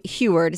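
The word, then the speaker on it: Huard: All right, so the Big Ten says USC should have been penalized after having reserve quarterback Sam Heward, is that how you say his name? – Yes. Yep.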